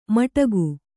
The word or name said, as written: ಮಟಕು